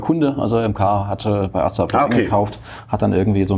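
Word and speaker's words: ASSA ABLOY: Ein gutes Jahr bei Essa Abloy, oder wie bin ich ausgesprochen? – Genau, Essa Abloy.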